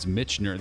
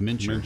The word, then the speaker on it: Mincher: They mispronounced him here. It's Michener. They put Michener, C-H, before the M, but it's Mincher.